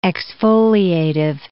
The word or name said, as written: exfoliative